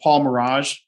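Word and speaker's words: Merage: And he's also a professor at the, is it Paul Mirage School of Business? Did I butcher that? – Okay.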